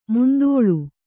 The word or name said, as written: ಮಂತುಳ್ಳಿ